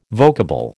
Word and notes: vocable: American English

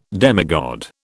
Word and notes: demigod: British English

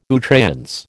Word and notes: à outrance: American English

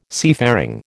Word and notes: seafaring: American English

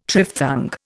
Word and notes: triphthong: Indian

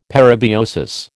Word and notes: parabiosis: American English

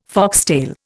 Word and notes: foxtail: Indian

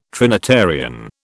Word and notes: Trinitarian: British English

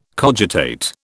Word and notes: cogitate: British English

